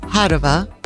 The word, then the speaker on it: Padova: Padova, as it's pronounced in Italian, is yet another jewel that should be on your list.